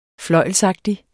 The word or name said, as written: fløjlsagtig